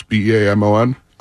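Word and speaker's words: Beamon: Bob Bowman, I believe is how you pronounce his name. It's Bamon, who was a jumper, and I don't know what this is, but it just says Komaneki.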